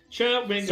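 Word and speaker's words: Chwingas: That's their name! I'm looking at the pronunciation guide. Chewingas.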